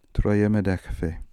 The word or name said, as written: Troemedekhfe